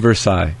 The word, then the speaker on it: Versailles: I can't pronounce it. – Treaty of Versailles. – Versailles. That's simple enough.